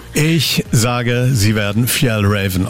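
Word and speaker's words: Fjällräven: Ich bin Franziska Köhler von der schwedischen Outdoor-Marke und es wird richtig ausgesprochen Fjell Raven. – Fjell Raven.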